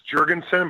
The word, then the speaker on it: Jergensen: Well, actually, to be fair to you, I think you said Jergensen. I mean, that really is the correct pronunciation. I mean, I'm Norwegian and Viking and Scandinavian, so it's Jergensen, but that's only because that's how my great-grandfather, when he came over, just said, let's Americanize it and do it that way.